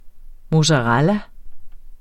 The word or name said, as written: mozzarella